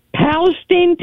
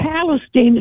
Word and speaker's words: Palestine: Palestine, Texas. – Okay, that sounded like a cow. Am I right? – What would she say? Halstein? – Isn't that a cow? – Palestine. It's spelled like Palestine, but Palestine.